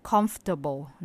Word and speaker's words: comfortable: มันไม่ใช่อยากจะให้ออกเสียงเป็น comfortable มีแค่สามพยาง comfortable